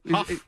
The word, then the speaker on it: Hough: Well, it was, is it Juliana, how do you pronounce it? Is it Ho? – Huff. – Huff? H-O-U-G-H?